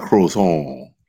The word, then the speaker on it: croissant: A croissant. – Yeah. That's all. You gotta roll that R.